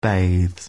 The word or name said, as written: bathes